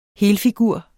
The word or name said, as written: helede